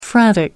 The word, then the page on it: frantic: American English